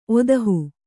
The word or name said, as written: ಒದಹು